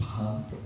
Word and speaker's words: Pagan: In 1983, I had a chance to go to Pagan, Burma, sometimes pronounced Pahan.